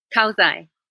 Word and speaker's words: kausay: With the K-A-U-S-A-Y. How do you say that? – Kauzai. – Kauzai.